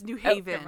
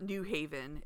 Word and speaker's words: New Haven: Oh, it's not New Haven. It's New Haven. On the second. What is it? Not accent. That's not the word. Emphasis. Thank you. – Emphasis. – On the wrong syllable.